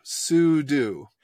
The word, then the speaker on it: sudo: But it's super user do, sudo.